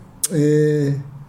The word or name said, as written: cee